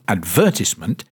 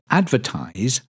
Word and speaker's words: advertise: Notice the difference in pronunciation between the noun advertisement and the verb to advertise. As we've heard, the third syllable in the noun advertisement is pronounced tis. Advertisement. But in the verb advertise, the last syllable is pronounced eyes, with the T sound added at the beginning.